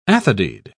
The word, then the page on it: athodyd: British English